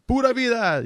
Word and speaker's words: Pura vida: Pura vida.